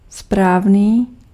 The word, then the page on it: bon: /bɔ̃/; /bɔ̃ː/